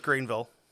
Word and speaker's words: Greenville: He was born March 23rd, 1949, down in Greenville, South Carolina. Grew up working on his family farm. – I think it's pronounced Greenville. – I'm pretty sure it's Greenville. – No. They pronounce it Greenville.